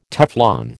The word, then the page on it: Teflon: American English